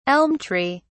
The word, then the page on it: elm tree: British English